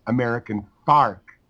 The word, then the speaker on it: American Fork: Yeah, and it started to get in so that the town of American Fork is pronounced American Fark.